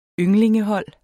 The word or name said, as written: ynglingehold